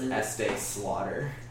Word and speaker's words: Estee Slaughter: It would be like Estee, like the makeup, like E-S-T-E-E, and then Slaughter. Because it's just adding S on the Estee Lauder.